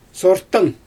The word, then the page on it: sortan: Xyzyl